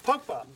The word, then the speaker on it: Pogba: Trying to say his name. – Pogba. – Beautiful.